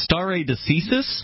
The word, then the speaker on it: stare decisis: He pronounced it, and I did look it up. It's stare decisis. – Stare decisis. – Latin, and in Latin it means let the decision stand.